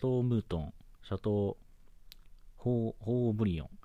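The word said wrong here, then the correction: Haut-Brion: あ、オーブリオン。 H 発音しないですね。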